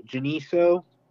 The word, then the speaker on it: Geneseo: It was called Geno, spells G-E-N-E-S-E-O, Geniso.